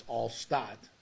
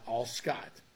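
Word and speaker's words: Alstott: Now it's, and by the way, young Nick, it's all Stott, not all Scott. It's all Stott. There's no C in there.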